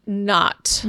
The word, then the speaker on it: Czubernat: Nat or net? – Not.